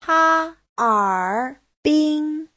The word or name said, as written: ha1er3bin1